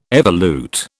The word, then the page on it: evolute: British English